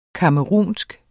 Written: camerounsk

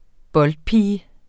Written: boldpige

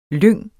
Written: lyng

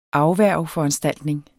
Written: afværgeforanstaltning

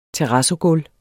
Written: terrazzogulv

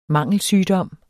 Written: MANGELSYGDOM

